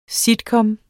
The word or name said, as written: sitcom